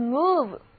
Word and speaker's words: move: 单词 move 的意思就是移动，拼写为 M-O-V-E， move，字母 O 的读音是长的 U。大家看 M-O-V 是个表示动的词根，而字母 E 是一个动词后缀，所以合在一起 move 就当作移动来讲了。